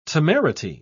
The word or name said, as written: temerity